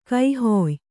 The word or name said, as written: ಕೈಹೊಯ್